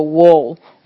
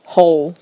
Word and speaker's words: whole: whole 这边整个是说整个，然后它是 w 是不太发音的，所以我们不会说 wall，我们会说 hole，就跟那个洞有点像，就是 h o l e 那个洞 hole 有点像。